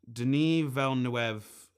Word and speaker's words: Denis Villeneuve: This movie, man, Denis Villeneuve, and I pronounced that name right, and I know I did.